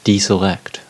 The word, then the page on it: deselect: American English